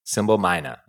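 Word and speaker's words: Simbelmynë: Symbolmina.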